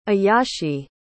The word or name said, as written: ayashe